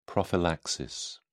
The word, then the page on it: prophylaxis: British English